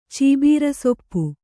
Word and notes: ಚೀಬೀರಸೊಪ್ಪು: kannada

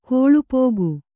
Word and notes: ಕೊಳ್ಪೋಗು: kannada

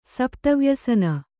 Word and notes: ಸಪ್ತವ್ಯಸನ: sapta vyasana

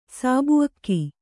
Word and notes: ಸಾಬುಅಕ್ಕಿ: Kannada

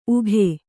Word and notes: ಉಗಿ: ugi

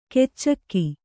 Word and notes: ಕೆಚ್ಚಕ್ಕಿ: keccakki

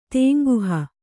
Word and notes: ತೇಂಗುಹ: tēŋguha

